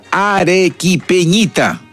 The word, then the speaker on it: Arequipeñita: Ari, que no me sale, ¿eh? Arequipeñita, así se pronuncia, Arequipeñita, de la inspiración de Ángel Torito Muñoz, y luego Mis Penas, también de la inspiración de este gran compositor y músico arequipeño.